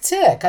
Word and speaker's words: Thich: We know it's wrong. I want to say that right now. We know we're pronouncing it incorrectly. However, I don't think Tick. I think Thich.